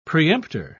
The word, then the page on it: preemptor: British English